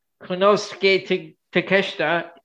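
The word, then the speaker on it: Konosuke Takeshita: I have the John Moxley, Konosuke, Takeshita. I actually said it right, so I've been saying it wrong the whole time.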